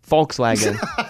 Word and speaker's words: Volkswagen: And if you want to say Volkswagen the right way, you'd pronounce the V as an F. Volkswagen.